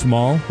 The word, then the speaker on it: Maul: Darth Maul? – Maul.